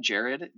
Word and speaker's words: Jared: So my name is Jared Quackenbush, and that is my Instagram handle. So it's at Jared, J-A-R-E-D.